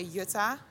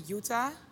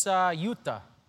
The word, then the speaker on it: Jutta: In America, your name is, they would say Jutta. – Yeah. – That's what I thought it was at first. – Yeah, in the Netherlands they say Jutta, but it's actually Jutta.